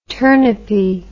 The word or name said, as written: turnipy